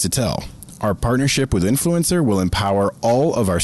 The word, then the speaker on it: INFLCR: Nonetheless, their brand name is INFLCR, and their pronunciation is influencer, if you couldn't figure that out.